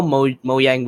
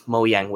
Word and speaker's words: Mojang: And some people think that it's the case of yes, okay, so employees at Minecraft at Minecon, they call Mojang with a J, but then other people pronounce Mojang with a Y. – Huh. – Because apparently Mojang in Swedish means gadget.